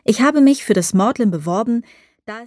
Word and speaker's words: Magdalen: Das College, dem ich angehören werde, ist das Magdalen, das seltsamerweise Magdalen ausgesprochen wird.